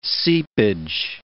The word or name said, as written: seepage